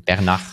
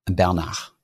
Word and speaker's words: Bernard: Der Bernard Arnault, genau den. – Bernard. – Ja, Bernard, genau.